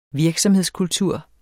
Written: virksomhedskultur

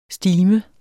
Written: STIMEN

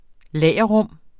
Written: lagerrum

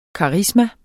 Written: karisma

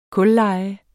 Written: KULLEJE

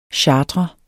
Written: chartrer

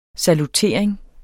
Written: salutering

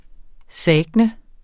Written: SAGTNET

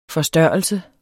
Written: forstørrelse

